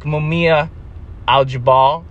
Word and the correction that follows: Mumia Abu-Jamal: Kevin Rashid Johnson, excuse me, Mumia Abu-Jamal, I knew I said that wrong.